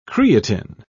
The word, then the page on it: creatine: British English